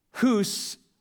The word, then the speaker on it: Hus: But his most famous line had to do with his name, because Hus rhymes with goose. It literally means goose.